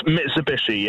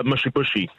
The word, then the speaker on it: Mitsubishi: So, it's Mitsubishi, yeah? – Yeah. Mm-hmm. – Yeah. – Yeah, he calls it a mushy bushy.